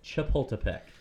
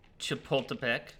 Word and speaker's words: Chapultepec: Why don't you tell us about the course that's hosting for only the third year in a row, Club de Golf Chapultepec. I went ahead and pronounced it for you so you didn't have to butcher it.